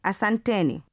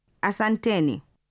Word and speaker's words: Asanteni: When expressing thanks to more than one person, you say Asanteni.